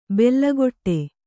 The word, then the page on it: ಬೆಲ್ಲಗೊಟ್ಟೆ: bella goṭṭe